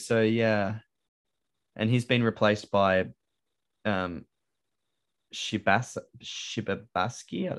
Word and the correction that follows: Shibasaki: I believe the name is pronounced Shibasaki.